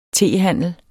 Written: tehandler

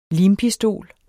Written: limpistol

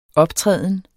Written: optræden

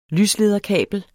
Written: lyslederkabel

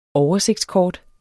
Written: oversigtskort

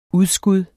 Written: udskud

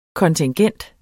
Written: kontingent

